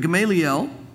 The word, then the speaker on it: Gamaliel: Then one in the council stood up, a Pharisee by the name of Gamaliel, it's pronounced different ways, I hear, but Gamaliel,